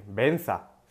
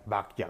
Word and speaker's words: Bhagya: Entonces, hay Benza, se escribe Bhagya, y pues puedes cantar BAGYA Guru en vez de Benza Guru.